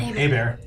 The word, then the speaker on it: Hebert: It was General Kargan, head of station staff, the Klingon forces here in Narendra, and Admiral April Ebert, both sat down with Olin. – Abear. – Sorry. Oh, Abear. – Rhymes with Hey Bear.